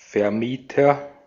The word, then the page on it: Vermieter: /fɛɐ̯ˈmiːtɐ/